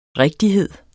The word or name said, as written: rigtighed